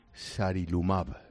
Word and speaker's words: sarilumab: Estáis trabajando con un ensayo, con una cosa que se llama sarilumab.